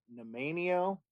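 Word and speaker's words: Nanaimo: And then they added, it's called Nemanio. I'm probably not saying that right. No, I think you nailed it.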